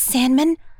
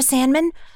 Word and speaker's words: Sandman: Um, y- yes, yes, Penny, it's Sandman. Sandra Sandman.